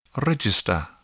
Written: register

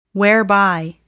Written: whereby

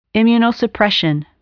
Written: immunosuppression

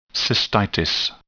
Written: cystitis

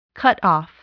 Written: cutoff

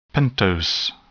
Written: pentose

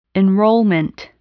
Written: enrollment

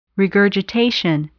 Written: regurgitation

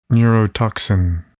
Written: neurotoxin

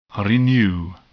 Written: renew

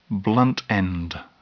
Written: blunt end

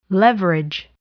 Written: leverage

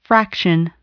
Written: fraction

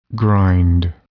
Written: grind

